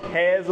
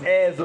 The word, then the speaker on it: heirs: And so that's what we really want to kind of think about this morning when we talk about heirs of the world, not heirs of the world. Please get the pronunciation right. This is one of those words where everybody's like, heirs, I don't know what it is. It's heirs of the world, all right?